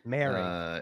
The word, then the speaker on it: Moehrig: Moehrig. Uh, yes, there's a little bit of an A sound in there.